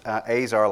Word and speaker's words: Azar: It's pronounced Azar, like razor.